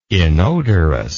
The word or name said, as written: inodorous